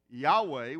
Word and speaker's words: Yahweh: And we pronounce it Yahweh. We have no idea how it's genuinely pronounced because it has no vowels or vowel points to give us indication of how it's pronounced.